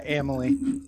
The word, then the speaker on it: Amalie: And, oh, Emily. Uh, yeah, um, is that how you say it? – Yeah, Emily.